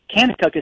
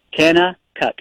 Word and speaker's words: Kanakuk: Canna Cook is spelled K-A-N-A-K-U-K. Canna Cook. Everything with a K there.